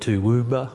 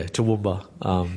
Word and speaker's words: Toowoomba: People call it Toowoomba or Toowoomba. It rolls off the tongue pretty easily for us locally, but yeah, I've heard some different ways that people have tried to pronounce it or say what it, yeah.